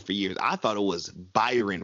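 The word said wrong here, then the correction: Bryon: Russell. I didn't know it was Brian.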